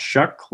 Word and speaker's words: Shough: You know, their main concern coming into the season was replacing Justin Herbert, but their new QB, Tyler Shuck, I believe is how you pronounce it. It's spelled S-H-O-U-G-H, but evidently it's pronounced Shuck, like people in Nebraska shuck corn.